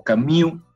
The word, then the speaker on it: Camus: Albert Camus, o Camus, para darle la pronunciación francesa, falleció joven, ¿no?